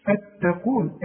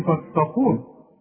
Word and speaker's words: فاتقون: شوف فتقون، الفه احنا خدناها دلوقتي، والتاء دائما ترقق في القرآن، القاف المفخمة. هنقول فاتقون، اوعى تقول لي فاتقون.